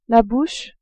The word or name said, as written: bouche